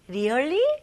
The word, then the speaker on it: really: R 발음은 일단은 천장을 닿으면 안 되고 혀가 뒤로 가는 느낌이 나야 됩니다. 그래서 really 네, 되게 버터 발음이죠. Really, really. 오케이? 그리고 여기에 E 발음은 조금 더 길게. 그래서 really 이게 아니고 really. Really? 네, 뭔가 고무줄 당기는 느낌입니다.